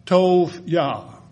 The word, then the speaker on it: Tobiah: But when Sanballat the Horonite and Tobiah, by the way, this is T-O-B-I-A-H, that I-A-H is J-A-H, Yah. And so be sure that you don't say it like Isaiah, Isaiah. You say Isaiah, Jeremiah. Okay, that's the short form of the name of God.